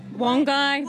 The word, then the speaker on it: Wangi: Well, now, my pronunciation is not necessarily correct, but Wangi? – Look, Wangi, Wongai, Wongai. – Wongai Falls. Okay, I'll go with whatever you tell me.